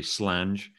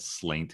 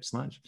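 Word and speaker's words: sláinte: I guess the toast, the very formal toast here would be to say slange, which is, though people think it's like said slaint, because that's just how it's spelled. But yeah, slange.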